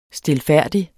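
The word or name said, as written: stilfærdigt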